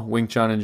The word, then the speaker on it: Wing Chun: Wing Chun in general. – Wing Chun.